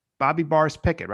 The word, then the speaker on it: Bobby Boris Pickett: And the other is Monster Mash by Bobby Boris Pickett, right? Am I saying that right? Is that the... – Yep. That is correct.